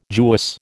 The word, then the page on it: Jewess: American English